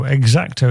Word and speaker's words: Exacto: Anyway, played a song from this band last month, an XTC tribute band in Australia, Exacto, X-A-C-T-O, Exacto is how you pronounce it.